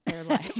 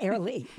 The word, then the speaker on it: Airlie: And that's spelled A-I-R-L-I-E. Air. Lie. – Airly. – But we pronounce it Airly much nicer.